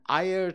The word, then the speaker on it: air: and Ayer cheese. Ayer is basically water. I know it looks like it's spelled like air, A-I-R, pronounced Ayer, and it's the Malay word for water.